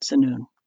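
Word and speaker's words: Tzunun: So Zanun, it's T-Z-U-N-U-N. So the T is silent, Zanun.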